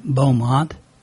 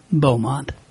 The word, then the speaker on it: Beaumont: Or maybe that's Beaumont. I've heard it pronounced both ways. Actually, thinking about it, it's almost certainly Beaumont. Hugh Beaumont. You know, Leave It to Beaver, the father. Spelled his last name the same way. And I'm certain he pronounced it Beaumont, for whatever that's worth.